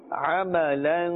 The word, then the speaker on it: عَمَلًا: عذابٌ شديدٌ قوماً غيركم قوماً غيركم عملاً صالحاً عملاً صالحاً. – Remember that ميم عامة, that ميم must be pronounced clearly. – عَمَلًا. – If we say too fast sometimes, it seems like we're saying عَمْلًا, عَمْلًا. But عَمَلًا, عَمَلًا صَالِحًا.